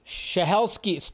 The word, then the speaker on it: Stahelski: And then, yes, yes, Chad Shadelsky, Stahelski, I never say his name right, says they've had talks about adding best stunts category to the Oscars.